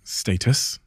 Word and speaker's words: status: One of the alarming things you talk about in this book is that status. Did I say that right? – Yeah. – Yeah, that's the English way I need to, because I've You're not American.